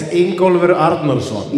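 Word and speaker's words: Ingólfur Arnarson: Engolfer. – How do you say it? – It's Ingolfur Arnarsson.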